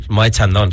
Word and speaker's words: Moët Chandon: Ayer me encontré en Instagram una story con un Moët Chandon. – Perdón. – Creo que se dice así. – Moët Chandon, sí.